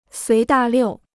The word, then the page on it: 随大溜: suí dà liù